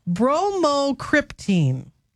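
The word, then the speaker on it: bromocriptine: Because the winning word that he correctly spelled was, it's pronounced bromocryptine.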